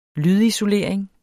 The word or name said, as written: lydisolering